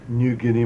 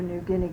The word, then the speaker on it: Niugini: New Guinea Mining. – That's spelled N-I-U... – N-I-U-G-I-N-I. That's the pidgin spelling for New Guinea.